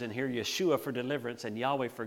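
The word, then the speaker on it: Yeshua (ישוע): Now, some of you are nodding because you realize that was how you would pronounce the name Jesus in Hebrew, Yeshua.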